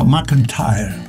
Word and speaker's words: McEntire: ¿Se dice así? – Reba McEntire. – McEntire, hay que darle ese R.